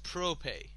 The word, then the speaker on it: Probe: Is it pronounced Prope? Okay, that's kind of awkward. It's kind of like propane.